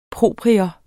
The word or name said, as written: PROPRIETS